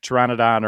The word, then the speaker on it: pteranodon: It's P-T-E-R, I believe. Yeah. So, you know, the pteranodon, the P is silent.